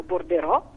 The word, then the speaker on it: bordereau: viene aggiustato solo foneticamente perché lo pronunciamo borderò, i giornalisti italiani lo pronunciano borderò e lo scrivono anche borderò, mentre in inglese si usa di più payroll.